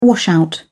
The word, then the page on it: washout: British English